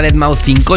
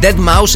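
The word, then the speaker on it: Deadmau5: Todavía hay gente que lo llama Dead Mouse 5, yo ya me mm, tiro la toalla diciendo a todo el mundo que por favor lo pronuncie correctamente.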